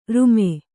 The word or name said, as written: ರುಮೆ